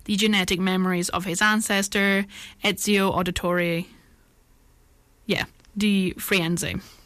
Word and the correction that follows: Da Firenze: Da Firenze, sorry. Absolutely butchered that, I'm sorry.